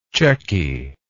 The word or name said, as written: checky